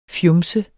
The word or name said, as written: fjumset